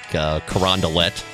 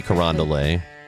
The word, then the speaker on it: Carondelet: So we were just talking with Scott Jagow about how we pronounce things wrong in St. Louis, like Carondelet. Yeah, Carondelet should be Carondelet.